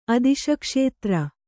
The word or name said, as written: ಅದಿಶಕ್ಷೇತ್ರ